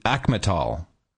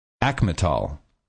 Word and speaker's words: Acmetal: So you spell it A-C-M-E-T-A-L. – A-C? – Alpha Charlie Mike Echo Tango Alpha Lima. A combination of ACME, a Greek word meaning the peak or the best, and capital.